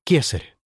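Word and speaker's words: Caesar: Римляне произносили слово «цезарь» как «кесарь».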